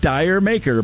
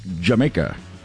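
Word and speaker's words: D'yer Mak'er: I know it's spelled like Dire Maker, but it's pronounced Jamaica.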